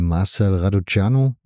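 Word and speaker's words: Marcel Răducanu: ansonsten, Raducanu hat sich, glaube ich, sehr gut angehört.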